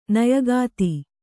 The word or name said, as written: ನಾಯಕಿತಿ